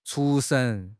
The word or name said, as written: 出神